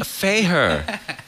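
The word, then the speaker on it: Fakre: Fayher.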